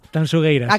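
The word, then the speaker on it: Tanxugueiras: Tanchugueiras. – Sí, esto me lo corrige Fermín Agustín mucho. Por favor, digo, mira que yo siempre pronuncio. A ver, otra vez, dímelo. – Tanchugueiras.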